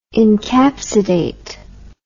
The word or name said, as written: encapsidate